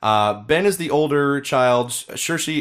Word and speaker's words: Saoirse: Basically, the story here is about two children, Ben and Saoirse, I believe is how you pronounce it. – Saoirse? – Like Saoirse Ronan. – Saoirse Ronan? – Yeah, the actress whose name is spelled with no H in it whatsoever.